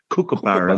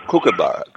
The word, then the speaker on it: kookaburra: A Coonabarra? – Coonabarra. You know, you're the bird. I'm probably pronouncing it wrong. – Kookaburra. – I'm sorry. I was pronouncing it wrong. – Kookaburra.